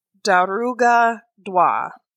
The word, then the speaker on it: Dearg Due: This is the story of Daruga Dwa. That's how you pronounce it in Irish, because it has Celtic origins instead of Gaelic origins.